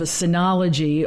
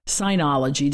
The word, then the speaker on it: Synology: My husband, who likes to gift me with tech because it's an easy get, decided that he would grant my request of a Synology or a Synology, depending on how you want to pronounce it, DS220 plus NAS system.